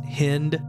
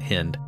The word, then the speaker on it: Hind: And I've also heard it pronounced Cynthia Hind, but I think the British pronunciation is Hind, and since she's British, we're gonna pronounce it Hind.